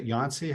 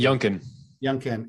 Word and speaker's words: Youngkin: And there was one poll released on Friday that had him down by 8% to, is it Yancey? How do you say the Republican challenge? – Youngkin.